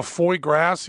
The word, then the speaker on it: foie gras: When I see the foie gras here, F-O-I-E-G-R-A-S, but my wife wrote it down phonetically for me. I have it on my computer, F-W-A-G-R-A-H, foie gras.